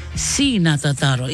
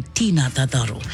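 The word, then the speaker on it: Ti na tataro: And T-I is C, is pronounced C, okay, not T. So the Tataro, the prayer in Kiribati goes something like this. Let us pray. Our Heavenly Father, we ask for your blessings this week to each and every one of us in Jesus' name. Amen. So you are going to say this. C na Tataro, it's spelled T-I. You can't say Tina Tataro.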